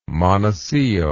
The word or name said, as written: monacillo